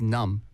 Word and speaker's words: numb: That's what it means is numb. – 맞아요. – N-U-M-B. – 맞아요. 네. N-U-M-B. Numb. 네. 하지만 우리 그 B 발음은 네. 안 나와요. – 그쵸. – 네. 그냥 numb.